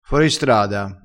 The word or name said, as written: foristrada